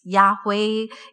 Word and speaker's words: Yahweh: And when "I am who I am," when it's pronounced in Hebrew, it's Yahweh.